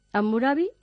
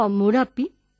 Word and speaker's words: Hammurabi: Hammurabi o Hammurapi, que ahora también se pronuncia con P en vez de con B, fue el sexto rey de la primera dinastía o dinastía amorita de Babilonia.